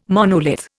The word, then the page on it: monolith: Indian